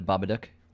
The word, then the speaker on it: Babadook: And that is how you pronounce it, The Babadook, like book.